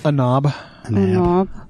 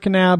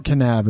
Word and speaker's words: Kanab: I wonder if anybody ever looks at the name Kanab and thinks that the N is, or the K is silent like in Knife. – A knob.